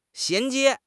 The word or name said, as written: 衔接